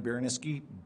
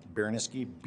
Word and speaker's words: Baraniski: Bryan Baraniski, B-R-Y-A-N, Baraniski, B-A-R-A-N-I-S-K-I.